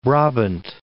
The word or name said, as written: Brabant